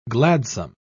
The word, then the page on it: gladsome: British English